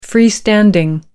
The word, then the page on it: freestanding: American English